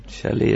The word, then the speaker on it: chalet: Chalet is a French word. I hope I say it correctly. Chalet, you have to say it like this.